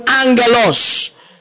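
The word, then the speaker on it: Angelos (ἄγγελος): Now, the word angel in the Greek is the Greek word angelos. A-double-G-E-L-O-S. The N is not there. But you find it in the pronunciation.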